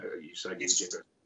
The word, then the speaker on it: Usagi Yojimbo: Is that Usagi Yojimbo? – That's how I say it.